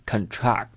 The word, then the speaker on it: contract: 再给大家举个例子，读成 contract。做名词的时候它的意思是合同，读成 contract，重音在前面。如果做动词呢，这个单词它的意思是收缩，比如说这个金属遇冷它也会收缩，重音在后面，读成 contract，重音在这里面啊，重音在后这里面。